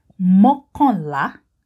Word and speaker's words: mokonla: Okay, 11 in Yoruba is mokonla. Mokonla. Mokonla means one more than 10. Okay? One more than 10 is mokonla. Though the 10 is silent, we just say mokonla.